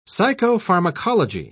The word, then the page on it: psychopharmacology: British English